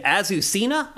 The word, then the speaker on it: Azucena: Named Azucena, I believe is the correct way to say it. – Azucena, yeah.